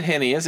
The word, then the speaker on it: heinous: Did I say that right? – Heinous. No. – Heinous. H-E-I, right? – Yeah. – H-I-E. – You said heinous. It makes sense. You said it right.